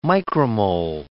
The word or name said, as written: micromole